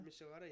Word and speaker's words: Michelada: Is it Michelada? – Yeah, it's a, yeah. – What does that even mean? – I don't know. – Is that how you say it? Michelada? – Michelada, yeah. It's one of them spicy beers.